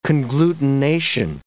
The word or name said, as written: conglutination